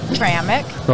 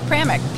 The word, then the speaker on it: Pramik: Pramik. – Okay, Pramik? – Pramik. P-R-A-M-I-K.